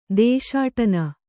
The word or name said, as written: ದೇಶಾಟನ